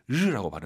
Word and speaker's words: 日: 이 날일자를 르라고 발음해요.